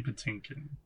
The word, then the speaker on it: Patinkin: And the other thing is, like, Manny Patinkin? Is that how you pronounce his last name? – Mandy Patinkin.